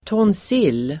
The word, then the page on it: tonsill: [tåns'il:]